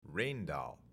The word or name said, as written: Rijndael